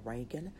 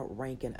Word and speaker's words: Rankin: I am on Instagram and I am on Twitter as just my first and last name, Katina Rankin. I am on Facebook at Katina Rankin Official, and I spell that with a K. It's K-A-T-I-N-A, and the last name Rankin, R-A-N-K-I-N.